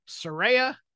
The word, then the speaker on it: Saraya: Big debut on AEW Grand Slam this week, Saraya, as she has told us it is pronounced.